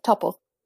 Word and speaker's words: Topol: Is it Heimtoppel? From Fiddler on the Roof. – Topol. – Tevye. Yeah, Topol.